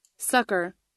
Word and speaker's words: succor: Okay, now, let me play how it's supposed to be pronounced. – Succor. – Succor. That's not how I've heard it pronounced. I've heard it pronounced succor.